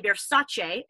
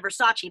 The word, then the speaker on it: Versace: He's got like these, you know, the classic Versace, which by the way, Donatella came out not long ago and said everyone's been pronouncing it wrong. It's actually Versace instead of Versace. – But Versace. Versace. So it's not Versace.